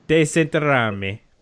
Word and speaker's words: desenterrarme: Desenterrarme. Wait, let me see. – Really? – There's an option. Desenterrarme. That's how you say it. I used the audio option for pronunciation.